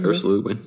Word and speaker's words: Ursula Le Guin: Ursula Le Guin. – Yep. Ursula Le Guin.